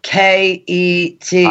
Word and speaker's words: Phuket: Hey, Bruce, how do you say the word P-H-U... Phuket. – I said it last weekend. – Phuket. – Phuket. – Phuket. – It's not Phuket.